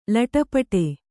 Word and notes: ಲೊಟಪಟ: Kannada

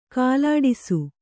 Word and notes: ಕಾಲಾಡಿಸು: kālāḍisu

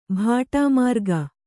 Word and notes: ಬಾಟಾಮಾರ್ಗ: bāṭāmārga

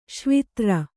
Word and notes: ಶ್ವಿತ್ರ: śvitra

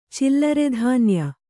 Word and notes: ಚಿಲ್ಲರೆಧಾನ್ಯ: kannada

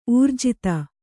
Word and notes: ಊರ್ಜಿತ: ūrjita

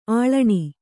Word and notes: ಆಳಣಿ: āḷaṇi

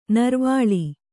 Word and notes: ನೀರುವಲೆ: nīruvale

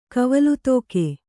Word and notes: ಕವಲುತೋಕೆ: kavalutōke

